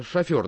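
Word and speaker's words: шофёр: Тогда шофер, да? – Да. – У меня с ударениями во французских словах большие проблемы. – Не, ну, шофер, второе, ну, да.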